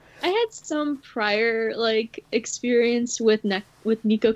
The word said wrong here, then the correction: Neko: Case. Sorry, I keep trying to pronounce it the other way around. – Neko? Yeah. – Neko, it's... Oh.